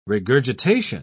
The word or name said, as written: regurgitation